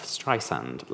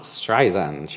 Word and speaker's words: Streisand: As for her second name, a frequent topic of discussion in her interviews and concerts is the pronunciation of Streisand, like sand on the beach. It's not Streisand, she often says, my friend Liza has a Z.